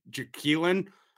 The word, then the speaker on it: Jaquelin: And the Jack, it's the Vikings press team put it out that it's like Jaquelin. So I've been mispronouncing his first name for years, but I don't even remember how the real one. So I just got to call him Jaquelin.